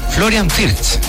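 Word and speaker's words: Florian Wirtz: Nos vamos al ataque, Leroy Sané, Jamel Musiala, Florian Wirt, no, es Florian Fierz. Así Florian Fierz, así se pronuncia.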